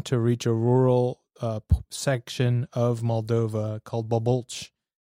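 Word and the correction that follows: Bulboaca: which I know you'll pronounce much better than I just did. Can you give me the pronunciation? – Yes, it is Bulboç.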